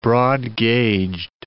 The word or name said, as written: broad-gauged